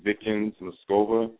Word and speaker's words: Vickens Moscova: I will go to Google right now and type in V as in Victor, I-C-K-E-N-S-M-O-S-C-O-V-A, and that's Vickens Moscova.